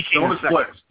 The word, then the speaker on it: Jonas Blixt: So the second guy, I'm going to ask for your help to pronounce his name, is Jonah Blixt. – Jonas Blixt, yep.